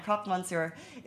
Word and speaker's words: croque monsieur: So first of all, if you're not aware, the photo up is beside me is a croque mamsur. A croque monsieur, that is my terrible French pronunciation of it. Let me try that again. Croque monsieur is a, a uh, traditional French dessert.